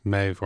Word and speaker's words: Medb: So are there more representations of Meiv or Medivh, as the old Irish pronunciation would have been Medivh, that's been modernized to just Meiv, straight Meiv? – Exactly, yeah.